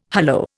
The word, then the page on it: hullo: Indian